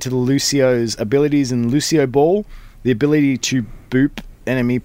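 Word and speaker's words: Lúcioball: So Lucio Ball. Did I say that right again? – Yes, that's okay. Cool. – Lucio Ball will introduce Sydney, Australia as a new map environment.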